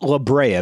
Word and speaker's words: La Brea: I'm glad you pronounced La Brea, because I was not sure how to pronounce that second word.